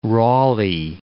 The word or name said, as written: Raleigh